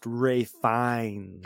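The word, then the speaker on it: Ralph Fiennes: It's pronounced Ray Fiennes.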